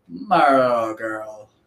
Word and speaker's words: Marlboro: I never know how to pronounce it, but Marlboro.